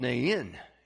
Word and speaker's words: Nain: Nain. And so if you want to pronounce it correctly, you have to make it a two-syllable word, Nain.